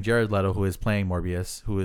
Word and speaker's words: Morbius: Morbius.